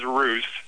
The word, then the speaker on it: Roos: My last name is Roos, and it's R-O-O-S. – Ah. Roos. – It's R-O-O-S? – Right. – Yeah. No, I care. How dare you? How dare you? Because here's the whole thing. R-O-O-S is Roos, and that's fine. I have R-U-S-S. – Yeah, in front of me, and then it says it rhymes with goose, so that's fine.